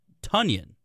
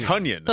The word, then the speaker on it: Tonyan: And so the way it was described is that his name is actually Tunyon, as in rhymes with Funyon. That's exactly how they described it. – So it's Tunyon.